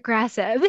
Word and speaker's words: adagrasib: Adagrasib. – Adagrasib. – These Okay. – names are sometimes hard to pronounce, but it's adagrasib, and it's an oral agent, so a pill that's taken twice daily.